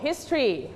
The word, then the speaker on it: history: only two syllable history